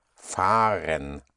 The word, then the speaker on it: fahren: If you go in traveling by in any way, but not on foot, would be fahren. Fahren. Fahren is spelled F-A-H-R-E-N, fahren.